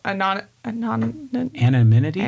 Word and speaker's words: anonymity: It's because there's a matter, some level of anonymity? Am I saying that right? – I think you are. – I'm mixing the M's and N's together.